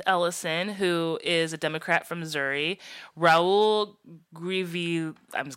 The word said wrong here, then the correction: Grijalva: going to say his name, Grijalva.